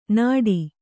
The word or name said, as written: ನಾಡಿ